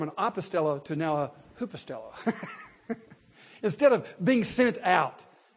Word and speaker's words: hupostello: It's hupostello. You might spell that H-U-P-O-S-T-E-L-L-O. H-U-P-A-S-T-E-L-L-O, hupostello.